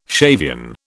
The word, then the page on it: Shavian: British English